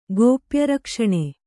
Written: ಗೋಪ್ಯರಕ್ಷಣೆ

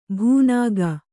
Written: ಭೂನಾಗ

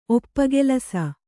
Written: ಒಪ್ಪಗೆಲಸ

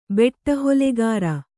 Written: ಬೆಟ್ಟಹೊಲೆಗಾರ

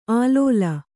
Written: ಆಲೋಲ

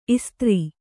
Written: ಇಸ್ತ್ರಿ

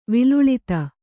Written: ವಿಲುಳಿತ

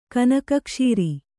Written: ಕನಕಕ್ಷೀರಿ

